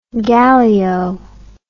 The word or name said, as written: gallio